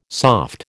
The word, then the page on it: soft: American English